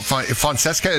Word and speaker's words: Fonseca: All right, if you want to get connected, Julio Fonseca, am I saying it right? Francesca? – No, Fonseca. – Fonseca. God, yeah.